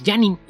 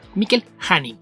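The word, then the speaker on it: Janín: Otro artista popular recientemente en DC es Mikkel Janin, que hay mucha gente que pensando en que lo está leyendo en inglés dice Janin, y aquí hay que recordar que es un artista de ascendencia francesa, entonces ahí la pronunciación es más cercana, como se ve en el español y otras lenguas romances, es como J, no como Y, Mikkel Janin.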